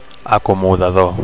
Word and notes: acomodador: Catalan

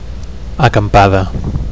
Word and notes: acampada: Catalan

